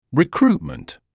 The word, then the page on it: recruitment: [rɪ’kruːtmənt]; [ри’круːтмэнт]